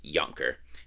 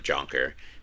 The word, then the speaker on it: Jonker: They moved in, so in Dutch, it's Yonker. They moved, his dad was a child and immigrated to Canada. When they got to Canada, everyone pronounced it Jonker with the J sound, and that's how they pronounced it.